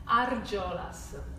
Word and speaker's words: Argiolas: Argiolas.